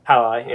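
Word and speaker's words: Jai Alai: Like, I like Jai or whatever. – Hi-Li. – Yeah, that's right. I'm an idiot. I think you're supposed to pronounce the J's.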